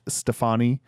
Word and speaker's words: Stephanie: Now, the interviews that I did watch, they pronounced her name Stefani, and she never corrected them. – Okay. – So I'm going to say it that way. It's Stefani. – But it looks like Stephanie. – It's spelled Stephanie. It looks just like Stephanie.